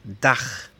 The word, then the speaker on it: Dach: Por ejemplo, la palabra para techo, Dach, D-A-C-H. O libro, B-U-C-H, Buch. Este sonido es el mismo que tiene la J en español como en Juan.